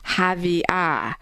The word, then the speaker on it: Xavier: So regarding the pronunciation of the saint's name, X or Z, you know, Norberto from Indiana emailed, it is pronounced Javier. The X is an H sound.